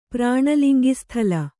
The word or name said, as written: ಪ್ರಾಣಲಿಂಗಿಸ್ಥಲ